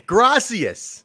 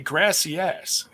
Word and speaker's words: Gracias: Gracias. You that guy? – Oh, is that, how you pronounce it? I always say grassy ass.